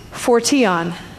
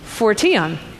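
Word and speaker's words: phortion (φορτίον): Phortion. That's actually what it says. It looks like portion, but it's phortion.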